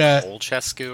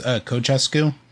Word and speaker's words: Ceaușescu: Isn't it like Olchescu or something? – It's pronounced Ceaușescu.